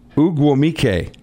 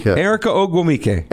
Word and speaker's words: Ogwumike: And Erica Ogmonde. – Pronounce that for me. – Let's see, that would be Ogwumike. Erica Ogwumike.